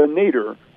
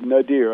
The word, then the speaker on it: nadir: Well, around 1890, we as a country entered into what historians call the Nader. Some people pronounce that word Nadir, and I think that both is okay. The N-A-D-I-R, the Nader of race relations.